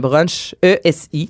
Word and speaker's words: Bruchési: Là, j'espère que je le dis bien B-R-U-N-C-H comme un brunch E-S-I. Donc, ça me semble, c'est Brunchesi à Saint-Hippolyte.